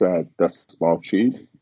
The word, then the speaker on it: Desmalchi: So my name is Alex Desmalchi, difficult last name, but it's spelled, it's pronounced just like it's spelled.